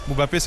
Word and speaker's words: Mbappé: Mbappé, c'est comme il le prononce ici.